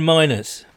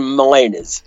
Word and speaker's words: Miners: like Quinn Marinez. Is that how you pronounce it? From Wisconsin Whitewater. Is that right? – No, Quinn Miners. – Quinn Miners. – Yeah.